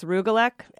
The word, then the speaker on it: rugelach: It's rugelach,